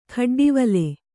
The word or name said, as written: ಖಡ್ಡಿವಲೆ